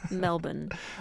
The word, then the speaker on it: Melbourne: In Australia, they pronounce Melbourne. – It. Thank you. – I wanted to say it the American way because I wasn't sure you'd understand me if I said it properly.